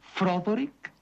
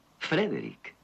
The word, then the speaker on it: Frederick: ¿Dice usted también Froderick? No, Frederick. ¿Y por qué no es Froderick Frankenstein? ¿Por qué no? Es Frederick Frankenstein.